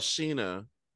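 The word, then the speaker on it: Scheana: But, girl, Sheena.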